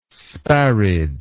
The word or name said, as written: sparid